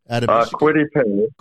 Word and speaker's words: Kwity Paye: Kwity Paye.